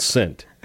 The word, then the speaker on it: scent: What letter is silent in the word scent? S or C? I say it's the C.